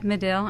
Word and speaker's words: Medill: Dr. Baker holds a Bachelor of Science degree in Journalism from the Medill, I think I pronounced that correct, Medill,